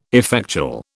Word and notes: effectual: British English

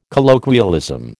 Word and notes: colloquialism: American English

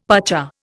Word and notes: pacha: Indian

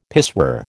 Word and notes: pissoir: American English

